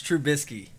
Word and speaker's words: Trubisky: Mitch Trubisky.